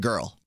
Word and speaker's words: GRL: And you're supposed to, you could pronounce it girl.